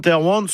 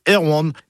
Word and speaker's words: Erwan: Mais la seule chose est sûre, Saint Erwan se prononce comme ça quand on parle de lui. Hein, car il était originaire du Trégor, et là-bas on disait Erwan.